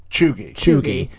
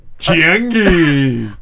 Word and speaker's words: cheugy: So Lazy E, you just said you never would have been able to pronounce it on your own without hearing Kara refer to it as... – Chugi.